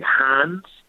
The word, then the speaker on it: Reims: It's spelled R-E-I-M-S, but it's pronounced, I think, Hans, to do some champagne tasting.